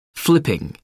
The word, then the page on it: flipping: American English